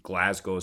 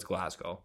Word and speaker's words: Glasgow: So many of you would say Glasgow, but I could tell you you're wrong because you don't live in Scotland and you don't have a Scottish accent, so it's Glasgow.